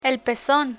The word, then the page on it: pezon: Spanish